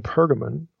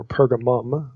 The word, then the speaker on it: Pergamon: It is a place specifically in Pergamon or Pergamum. Okay, you see it pronounced either way and spelled either way.